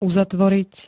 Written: uzatvoriť

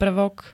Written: prvok

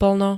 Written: plno

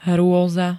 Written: hroza

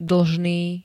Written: dlžný

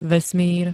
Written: vesmír

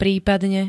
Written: prípadne

